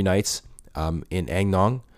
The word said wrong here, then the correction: Ao Nang: And I'm probably saying that wrong, but whatever. Which is the main town in Krabi. Ao Nang. Sorry, I've been saying it wrong this whole time.